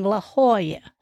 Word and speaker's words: La Jolla: They often drop the letter J used in words and replace the J's with the letter H or the H sound. For example, you would pronounce those two cities you want to visit, San Jose, or San Jose, and La Jolla."